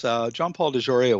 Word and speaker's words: John Paul DeJoria: We talked about Operation Piacan, and your new ship, the John Paul DeJoria. Am I pronouncing that correctly? – Yes, John Paul DeJoria was a supporter for Sea Shepherd, and we had a ship in Mexico protecting the Paquita called the John Paul DeJoria.